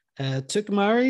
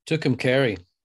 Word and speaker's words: Tucumcari: They go off, and we cut from that scene to Kim at Tucumari. Is that how you say it? – Tucumcari.